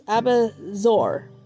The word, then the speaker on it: abat-voix: And we're going back to the New Imperial Encyclopedia and Dictionary from 1909, is Abidzar. And that is not spelled, or that is not pronounced the way it is spelled. It's Abidzar.